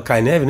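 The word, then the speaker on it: Cynefin: que se escreve Cinefim, mas fala Kaineve, não é isso, Vinícius?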